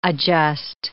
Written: adjust

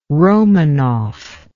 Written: romanov

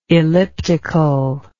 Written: ellipticalness

